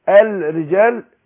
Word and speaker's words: الرجال: طب انا نطقت بعد همزة الوصل الراء، طب فين اللام؟ بقول واللام بتاعت هقول الرجال مش هتيجي. ليه؟ لان اللام هنا مضغمة، اللام هنا مضغمة.